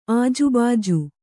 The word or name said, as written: ಆಜೂಬಾಜು